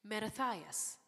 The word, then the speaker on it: Mattathias: And pronounce his name for me again. Mattathias, okay? And Mattathias was a Maccabee.